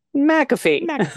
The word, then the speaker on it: McAfee: Judge Scott McAfee, last name is pronounced McAfee, like the antivirus McAfee.